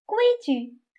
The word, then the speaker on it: 规矩: 预习，我知道“ 矩” 在“ 规矩” 这个词中读轻声“ 规矩”，而单字时读三声“ 矩”。